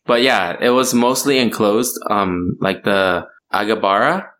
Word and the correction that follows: Agrabah: Is that how you say it? What's the Aladdin thing? – Agrabah? – Yeah, Agrabah.